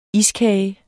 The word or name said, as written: iskage